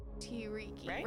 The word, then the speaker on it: Tiryki: T-I-R-Y-K-I. – I think that makes sense. – Tiriki? – Because if it's how it looks, then it would be T-Riki. – Tiriki, right? Yeah. – Tiriki. – Anchorage. – Yeah. One, two, three. – Tiriki.